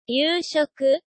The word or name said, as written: yuushoku